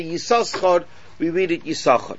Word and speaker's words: Yisachar: So really it should be pronounced Yisachar. But we don't pronounce it Yisachar, we pronounce it Yisachar, the second shin is silent.